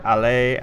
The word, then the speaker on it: Haller: Sebastian, as us non-French would say, Haller or Allais, as he is being pronounced by the French.